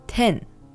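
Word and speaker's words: ten (点): In Japanese, this is pronounced ten.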